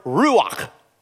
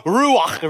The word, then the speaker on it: ruach: The word Spirit is the word, the Hebrew word, ruach. Now, if you're going to say it right, you got to go, kah, kah, which means everybody's going to end up with a coronavirus before the service is over.